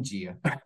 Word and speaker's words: dia: Nossa, a pronúncia de dia é bem diferente É, do que di, espanhol. di, di.